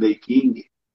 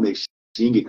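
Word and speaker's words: Nei Jing: Quem, quem tiver curiosidade de, de, de, olhar o original, por exemplo, do Ney King, é, ou Ney Xing, cada um tem a a pronúncia que preferi,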